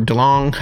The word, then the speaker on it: DeLonge: And then there was the whole part with Tom DeLonge or DeLong, how do you say his last name? But with a G, I do the hard G. Yeah. – Hard G, DeLong,